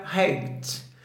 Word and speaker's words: height: altura em inglês se escreve com H e se pronuncia height.